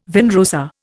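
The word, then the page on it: vin rosé: Indian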